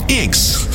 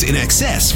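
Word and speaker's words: INXS: Back to 1988 for the band that most folks mispronounced when they first came out. They called them Inx. That's In Excess with New Sensation.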